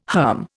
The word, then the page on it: herm: Indian